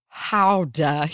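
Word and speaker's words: Gouda: I can indeed confirm that the cheese is pronounced howdah here with a guttural GH sound, which, yes, I know I didn't do that, but I gave an attempt.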